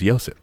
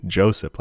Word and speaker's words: Josip: His first name is Josip. – Ah, like with a P. – Yeah, but like starting with a Y, I was saying Joseph like an idiot, so sorry.